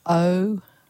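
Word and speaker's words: Oumuamua (ʻOumuamua): Oumuamua.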